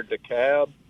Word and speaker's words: DeKalb: Hey, so I wanted to mention Sipple's Watermill down in Kemper County near DeKalb.